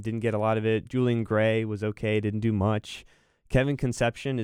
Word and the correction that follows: Concepcion: is one of my favorite ones. Maybe it's pronounced Concepcion. I remember hearing this stuff. – KC Concepcion.